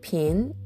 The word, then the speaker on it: 瓶: で、この場合、ピンという後に来るね、えー、ビンという、えー、文字。これは PING と発音記号で書きまして、第 2 世、下から上にずり上がる音になります。